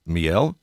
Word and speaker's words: Miel: The other main character, and I hope I'm pronouncing this correctly, it's miel? – Yep, that's right. Just like the French word for honey, basically.